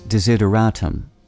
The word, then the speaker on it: desideratum: The Word of the Day for June 26th is desideratum, spelled D-E-S-I-D-E-R-A-T-U-M.